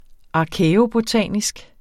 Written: arkæobotanisk

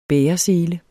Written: bæresele

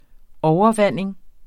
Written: overvanding

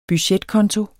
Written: budgetkonto

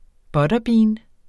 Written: butterbean

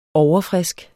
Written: overfrisk

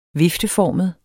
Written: vifteformet

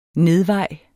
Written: nedvej

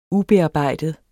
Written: ubearbejdet

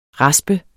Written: RASPENDE

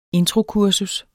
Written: introkursus

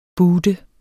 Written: boote

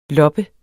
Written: lob

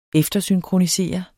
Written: eftersynkronisere

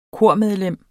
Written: kormedlem

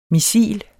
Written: missil

